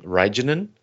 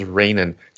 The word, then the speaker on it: Reijonen: It's spelled R-E-I-J-O-N-O-N, or O-N-E-N. That's how her last name is spelled. So I was saying Reijonen, or something like this. She says it's Rainin, just like Rainin. It's Rainin out.